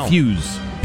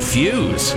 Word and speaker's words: Feuz: F-E-U-Z. – Rooting for some damn Swiss. – How do you pronounce it? F-E-U-Z. – He's the guy. – He's going to be the face of men's downhill. Fuse. – Beat Fuse? – Yeah.